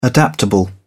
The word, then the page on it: adaptable: British English